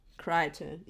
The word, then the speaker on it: Crichton: Crichton. Is that how you say that word? – I'm pretty sure. – It's C-R-I-C-H-T-O-N. So I just don't know how to pronounce it. – Crichton. Yeah, it's Crichton. It's Crichton.